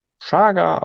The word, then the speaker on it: Chaga: Chaga, auch einfach Chaga ausgesprochen, auch wenn es CH, Chaga, das kommt aus dem Sibirischen.